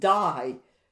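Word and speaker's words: die (δεῖ): He said, on this account, on account of this, therefore, we ought, and that word ought in the Greek is pronounced die.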